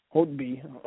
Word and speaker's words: Holtby: Um, Justin Williams had a, a hat trick and uh, their their uh, starting goalie Brandon um uh Holtley Holtby Holtby whatever you want to pronounce his name